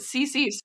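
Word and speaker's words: ceci: I don't know how they pronounce it. CC, ketchup. There you go. You know it.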